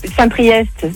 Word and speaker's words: Saint-Priest: T'as Saint-Priest, c'est EST, tu le prononces.